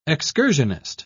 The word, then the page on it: excursionist: British English